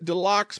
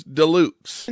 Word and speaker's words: Deluxe: I would pronounce it Deluxe, but the announcer says Deluxe.